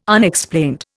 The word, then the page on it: Unexplained: Indian